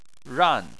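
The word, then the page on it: ran: tone 4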